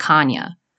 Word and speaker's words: Chania: The city of Chania, which is spelled Chania, but it's pronounced Chania, is where piracy began.